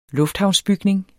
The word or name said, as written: lufthavnsbygning